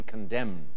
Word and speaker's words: condemn: What is N? – N in condemn.